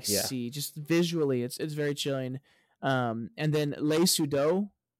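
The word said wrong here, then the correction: Léa Seydoux: Am I saying her name right? – I think it's Leah Seydoux. – Seydoux, not Sudo.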